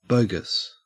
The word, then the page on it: bogus: Australian English